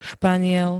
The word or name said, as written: Španiel